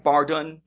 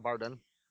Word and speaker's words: Bardun: We meet Bardon, who, and I'm saying that right? Bardon, B-A-R-D-U-N. – Yeah, that's what I was calling him, Bardon.